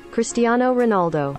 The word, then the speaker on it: Cristiano Ronaldo: Cristiano Ronaldo.